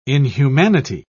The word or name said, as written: inhumanity